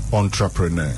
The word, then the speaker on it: entrepreneur: E-N-T-R E-P-R E-N-E-U-R Entrepreneur. Entrepreneur. – Mm. – It is not entrepreneur. – Yeah. – So if you want to say it right, entrepreneur. – Yeah. Similar to enclave.